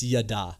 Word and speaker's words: yada: What is that word? It's yada. Yeah. – Yada. – Yada, yeah. Is how you pronounce it.